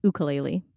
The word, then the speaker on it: ukulele: And the ukulele for me, actually, it's pronounced ukulele, but we won't be too harsh. – Thank you. I had no idea. You're saying that the first U is an U? – Yeah, it's a Hawaiian word.